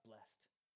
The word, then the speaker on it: blessed: Even like adding that extra syllable, it's not blessed. You can say blessed, and then all of a sudden it's like really spiritual.